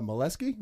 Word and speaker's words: Meleske: We have Marissa Meleske.